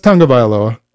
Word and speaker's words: Tagovailoa: No, it's Tagovailoa. – That's pretty good. – Yeah. It's. No, I mean, I'm a fan, so I couldn't call myself a fan if I couldn't at least pronounce his last name. – Yeah, but still. – And it's not spelled how it's said, which is the worst part about it.